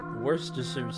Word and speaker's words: Worcestershire: Worcestershire sauce.